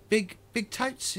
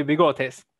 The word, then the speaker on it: Bigotes: So we'll go to Big Totes? Is that how I pronounce it? Big Totes? – It's actually Bigotes. It's mustache in Spanish.